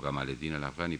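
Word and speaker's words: Jamal al-Din al-Afghani: por Jamaleddin al-Afghani o Gamaleddin al-Afghani, pronunciado a la egipcia.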